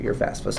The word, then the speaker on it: FAFSA: And oh yes, FAFSA. I knew I was missing. There was three. – You pronounced it better than I did. FAFSA.